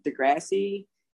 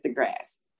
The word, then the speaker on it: deGrasse: I always want to say Degrassi. It's deGrasse Tyson, the astrophysicist.